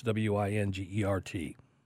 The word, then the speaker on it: Wingert: I know it's Wingert.